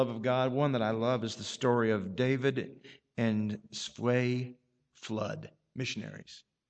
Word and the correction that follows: Svea: I can hardly pronounce her name, S-V-E-A. David and Svea. Svea Flood.